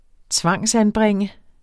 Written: tvangsanbringe

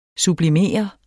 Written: sublimere

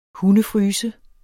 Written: hundefryse

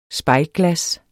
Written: spejlglas

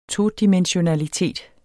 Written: todimensionalitet

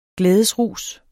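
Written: glædesrus

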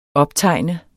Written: optegne

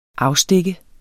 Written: afstikkende

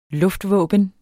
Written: luftvåben

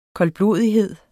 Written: koldblodighed

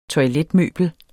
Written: toiletmøbel

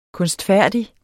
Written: kunstfærdig

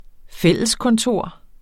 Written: fælleskontor

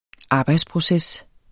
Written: arbejdsproces

